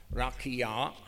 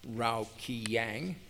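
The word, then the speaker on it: Raqia: The explanation of facsimile 2, figure 12 reads, and I'm going to pronounce this correctly, Rakia, not Rao Kiyang.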